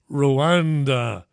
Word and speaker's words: Rwanda: It gave a last-minute ruling preventing the departure of a plane carrying asylum seekers to Rwanda. Is that how you say Randa? R-W-A-N-D-A. Randa. It's Rwanda. That's how you say it, I'm reliably told or informed.